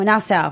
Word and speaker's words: Nassau: No, Nassau. Mm-hmm. Nassau. Yeah, I didn't want to pronounce it. You pronounced it right.